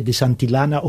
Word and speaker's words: Santillana: N'hi ha un altre, menys conegut, Giorgio de Santillana, pronuncio a la catalana, però in realtà hauria de ser de Santillana, o com fanno gli americani, només amb una L, no?